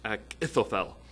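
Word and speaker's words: Ahithophel: If you go into Strong's, it says it's just like Ak. So it's Ak-Ithophel.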